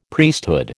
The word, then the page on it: Priesthood: American English